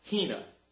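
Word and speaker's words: hina (ἵνα): It's pronounced hina.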